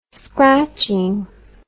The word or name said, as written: scratching